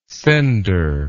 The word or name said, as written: sender